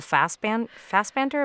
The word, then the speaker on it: Fassbender: Same thing with Michael Fassbender.